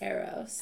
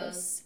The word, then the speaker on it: Eros: Eros. Am I saying that right? E R O S. – Eros. Eros. Eros. I would say Eros. Eros. That's Eros. like the Cupid god of whatever.